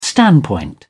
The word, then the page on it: standpoint: British English